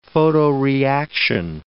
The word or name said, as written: photoreaction